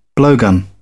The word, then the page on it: blowgun: British English